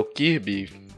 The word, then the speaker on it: Kirby: Kirby ou Kirby, você pronuncia da forma como quiser. Eu sempre pronunciei Kirby, aí hoje, depois de velho, eu inventei de pronunciar Kirby.